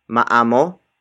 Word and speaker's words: maamo: For example, maamo ang aso niya. Maamo ang aso niya. Maamo ang aso niya. It means his or her dog is well behaved. Word for word, it's maamo ang aso niya.